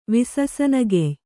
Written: ವಿಸಸನಗೆಯ್

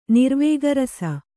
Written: ನಿರ್ವೇಗರಸ

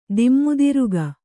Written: ದಿಮ್ಮುದಿರುಗ

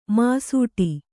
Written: ಮಾಸಟೆ

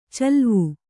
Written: ಚಲುವೆ

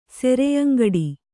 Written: ಸೆರೆಯಂಗಡಿ

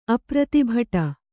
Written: ಅಪ್ರತಿಭಟ

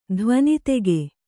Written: ಧ್ವನಿತೆಗೆ

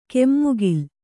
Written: ಕಮ್ಮಗೋಲ್

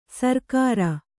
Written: ಸರ್ಕಾರ

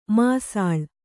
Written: ಮಾಸಾಳು

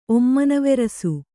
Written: ಒಮ್ಮನವೆರಸು

